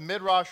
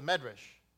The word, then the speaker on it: Midrash: We're going to open up the Midrash Rabbah. Or, depending if you come from the Ashkenazi sect, it's the Medrash. Nevertheless, the fact that it's spelled in Hebrew, Midrash. Some say it's Medrash.